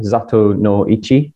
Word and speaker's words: Zatoichi: His name literally means blind one, Zato no Ichi, as it is often pronounced in the films.